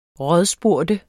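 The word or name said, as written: RÅDSPURGT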